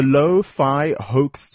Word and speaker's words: Lo-Fi Hoax: It's the Lo-Fi Hoax Tour. Did I pronounce it correctly? – Correct. Yes. – There we go. And it's L-O, so for the low, F-I for the fi, and H-O-A-X, of course, for the hoax tour.